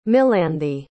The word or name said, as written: melanthe